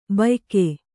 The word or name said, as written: ಭೂಯುಗ